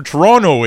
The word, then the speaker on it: Toronto: They don't pronounce the second T. It's silent. – Toronto.